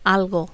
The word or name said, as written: algo